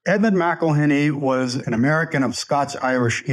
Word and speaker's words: McIlhenny: And you mentioned this name, Edmund McElhenney. Is that how we pronounce it? – McElhenney.